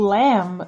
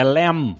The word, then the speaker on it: lamb: Porque o, o Lem, aliás, não se pronuncia o B, né? – Não se pronuncia. Aí, dagal, é isso. É Lem.